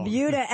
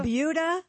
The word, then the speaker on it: Buda: It's called the Buda. – Buda. – Skip over. – Buda. – It's spelled B-U-D-A, and I looked it up. It is pronounced like beauty.